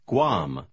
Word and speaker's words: Guam: Иногда комбинация G-U-A в начале слова звучит как «гу». Попробуйте прочитать номер 9. – We like Guam.